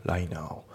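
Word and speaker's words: right now: だからこれがこれをね待ち伏せて聞いてでそれを聞いた時にはもう遅いみたいになっちゃうから、まあやっぱり良くないよねということで、とにかくね流れを追うと、ねいうことが大事です、うん、あと発音ですけどライトの T が抜けますね。ライナーライトナウじゃなくてライナーですね。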